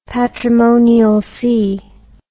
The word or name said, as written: patrimonial sea